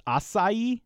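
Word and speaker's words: açaí: It's acai, yeah. You were close. The emphasis is on the last part. But yeah, most people think it's like an ack in there, or acai is what I always had said.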